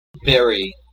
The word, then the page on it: berry: /ˈbɛɹ.i/